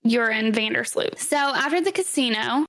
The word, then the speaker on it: Joran: It's spelled J-O-R-A-N, but it's pronounced Urine.